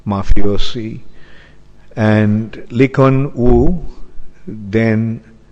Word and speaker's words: Lee Kuan Yew: And the Lee Kuan Yew, did I pronounce the name all right? Lee Kuan Yew, okay.